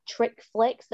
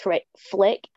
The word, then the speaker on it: trich flick: It's at trich flick, so it's T-R-I-C-H, so it's trich, but it's pronounced trich flick, F-L-I-C-K, so it's like chick flick but trich flick.